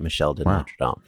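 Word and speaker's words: Michel de Nostredame: Oh, yeah, you said it right. Michel de Notre Dame. You just said it with a more French accent than me.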